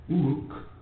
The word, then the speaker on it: Uruk: And this is another explanation of Uruk, which is spelled U-R-U-K, Uruk.